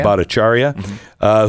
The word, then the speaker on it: Bhattacharya: Three, take for example Stanford's Dr. J, I can't pronounce his name, who argued, Bhattacharya? – Bhattacharya, Who argued that COVID lockdowns would harm children.